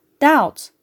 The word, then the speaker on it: doubt: Eğer T harfinden önce B harfi varsa B telaffuz edilmez. Örneğin doubt, debt ve subtle.